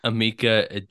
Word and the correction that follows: Emeka: It's pronounced. I think this one is pronounced Emeka. – Oh, Emeka. Got it.